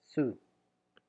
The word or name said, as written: sou